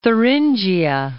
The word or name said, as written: Thuringia